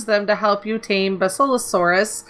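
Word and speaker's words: Basilosaurus: The Bacillosaurus. I think that's how it is. – You said it right the first time. – Awesome. I always think of the herb basil. – Mm-hmm. Basil. – Bacillosaurus, as they can stun all the manta that follow.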